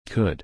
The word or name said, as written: could